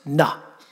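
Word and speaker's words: na (נא): So in the original Hebrew text, there is a little word pronounced na. It's N-A in our language.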